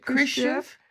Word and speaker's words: Khrushchev: Khrushchev.